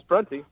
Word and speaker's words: Prunty: Frankly, I'm not even sure if I'm saying that right. I think it's Prunty, right? – Oh, yeah, it's Prunty.